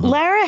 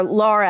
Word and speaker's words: Lara: Lara Harrington, or Laura, actually, she pronounces it.